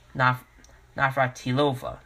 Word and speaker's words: Navratilova: I can't even pronounce her name. Navratilova. There we go.